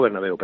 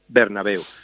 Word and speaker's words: Bernabéu: Don Santiago Bernabéu, perdón, que me equivoqué y lo he dicho mal. Don Santiago Bernabéu.